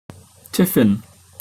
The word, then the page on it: tiffin: British English